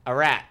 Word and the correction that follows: Ararat: Nope. I said it wrong. A ra-rat. Oh, boy. A-R-A.